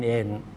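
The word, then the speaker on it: เอน (円): เยนก็อิจิเยนครับ 2 เยนก็นิเยน 3 เยนก็ซังเอนครับถ้าเป็น 10 เยนก็จูเอน 22เยนนิจูนิเอนคือเอาคำว่าเอนไปต่อท้ายนะครับต่อท้ายตัวเลขมันก็จะเป็นราคาเยนตามตัวเลขที่เรากล่าวไปข้างหน้านะครับ 8,000เยนฮัตเซนเอน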